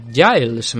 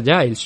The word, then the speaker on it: Giles: Trevin Giles. Me he enterado que se pronuncia Giles.